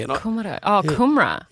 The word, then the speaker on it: kumara: But kumara. Kumara. Kumara is the right pronunciation.